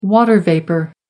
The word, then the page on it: water vapour: American English